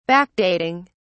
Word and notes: backdating: British English

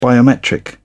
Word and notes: biometric: British English

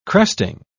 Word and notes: cresting: British English

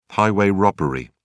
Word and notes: highway robbery: British English

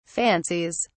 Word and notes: fancies: British English

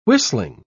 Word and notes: whistling: British English

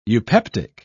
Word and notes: eupeptic: British English

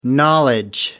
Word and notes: knowledge: American English

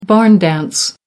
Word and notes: barn dance: American English

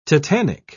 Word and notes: tetanic: British English

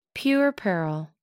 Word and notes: puerperal: American English

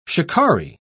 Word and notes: shikaree: British English

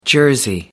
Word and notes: jersey: American English

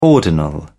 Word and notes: ordinal: British English

